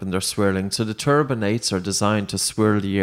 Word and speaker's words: turbinates: And is it turbinates? Is that how you pronounce it? – Yes. Yeah,